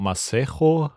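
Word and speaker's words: Masego: Von, äh, ich glaube, Masejo spricht man ihn aus.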